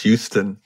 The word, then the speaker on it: Houston: And it's actually pronounced Houston.